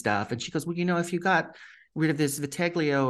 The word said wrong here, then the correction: vitiligo: or whatever you pronounce it on the thing." – Vitiligo. Really nice.